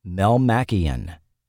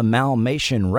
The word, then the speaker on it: Melmacian: In the very first episode, the singers mispronounced the word Melmacian. Watching episodes back-to-back, you will note "There Ain't Nobody Like You in the Malmation Race" became "'Cause There Ain't No One Like You in the Melmacian Race" on episode 2.